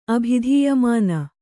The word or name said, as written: ಅಭಿಧೀಯಮಾನ